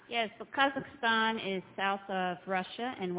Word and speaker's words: Kazakhstan: Kazakhstan.